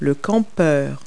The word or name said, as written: camper